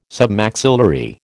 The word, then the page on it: Submaxillary: American English